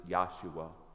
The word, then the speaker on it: Yahshua: Really, you pronounce it Yahshua the word Yahshua means salvation. He is the water of life.